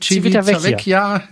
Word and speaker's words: Civitavecchia: Civitavecchia, ja, genau.